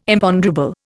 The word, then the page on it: Imponderable: Indian